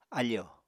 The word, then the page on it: áfò: apatani